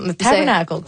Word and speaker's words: Tabernacle: Comment on la prononce, cette salle-là? – Le tabernacle. – Parfait. C'est ça.